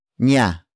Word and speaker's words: niya: Niya. Niya. Niya is spelled as N-I-Y-A.